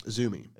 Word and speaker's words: Azumi: She's facing, I've forgotten how to pronounce this now. – Azumi. – Azumi, thank you.